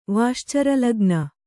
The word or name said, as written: ವಾಶ್ಚರಲಗ್ನ